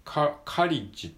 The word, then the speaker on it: college: カリッジっていう感じですね。